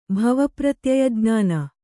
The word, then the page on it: ಭವಪ್ರತ್ಯಯಜ್ಞಾನ: bhava pratyayajñāna